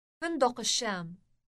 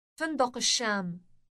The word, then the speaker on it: فندق الشام: فندق الشام. فندق الشام. – Note the elongated sh sound.